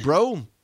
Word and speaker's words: Breaux: Moving on to Lafayette, Louisiana, and Todd Bro. And I love calling him Todd Bro, even though it's spelled B-R-E-A-U-X.